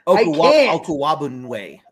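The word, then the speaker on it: Okwuegbunam: And like I said before, you know, we had a couple key drops, one from Judy, one from Albert Ogwebanam. – No, you got to say it like the announcer. – I can't. – Okuwabunwe. Okuwabunwe. I think that's how he said it.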